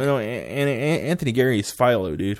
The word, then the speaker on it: Anthony Geary: And I mean, I thought Anthony Geary, is that how you pronounce his name? – Yeah.